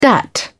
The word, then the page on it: dat: British English